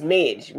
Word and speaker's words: Mage: how it became Trackmage, which probably wasn't such a great idea because a lot of people have trouble pronouncing Mage or Mage, depending on the English that you use. So it's Mage, like Major, Trackmage.